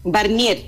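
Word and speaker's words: Warnier: ¿Dije bien tu apellido? – Barnier, así se pronuncia. – Barnier, perfecto. – Sí. – Perfecto. Es que la W es alemán, ¿no? – Es alemán, exactamente. – Claro, sí. Es que cuando los alemanes la pronuncian como si fuera la B corta, los británicos o los anglosajones la pronuncian Guarnier, podríamos decirlo de alguna manera.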